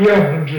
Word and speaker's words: young'uns: For that short pronunciation, it's talking about young'uns instead of say young ones, it didn't explain. Young'uns, you see, that is leaving off a part of the pronunciation here.